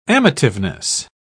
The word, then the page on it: amativeness: British English